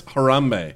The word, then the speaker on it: Harambe: Karambe. – Wait, is that how you say his name? I don't even know. – It's Harambe.